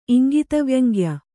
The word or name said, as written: ಇಂಗಿತವ್ಯಂಗ್ಯ